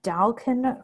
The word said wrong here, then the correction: daikon: radish, no, daikon radish, and jicama spelled with a J, J I C A M A, jicama.